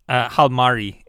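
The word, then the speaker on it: Jalmari: Yeah, Halmari. Yeah, because you know the J is pronounced H.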